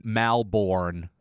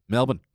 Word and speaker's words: Melbourne: But, I mean, so many Americans pronounce Melbourne, Malbourne. – Or yeah, Melbourne. – So, that's too many vowels. – Yeah. Pretend there are none. – Melbourne.